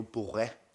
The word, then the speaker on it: Borg: Men nu siger du Borg, det er faktisk, jeg udtaler også forkert, det er jo Borre.